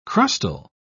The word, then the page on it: crustal: British English